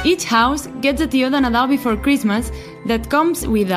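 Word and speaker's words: Tió de Nadal: Tio de Nadal.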